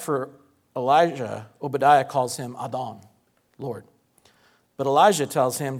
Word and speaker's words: Adon: It's the Hebrew word Adon, which Zeb thankfully helped me out and told was not pronounced Adon, because that's what the phonetic thing I found said. So I went to a Hebrew expert before I did this. Adon.